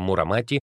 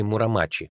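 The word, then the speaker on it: Мурамачи (室町): Таким образом, княжество Тёсю неизбежно становится Чосю, а эпоха Мурамати — эпохой Мурамачи, то есть приближается к японскому произношению этих слов.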